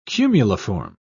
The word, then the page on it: cumuliform: British English